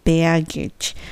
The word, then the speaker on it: baggage: この発音はベアグにゲージがつきますからベアゲッジ。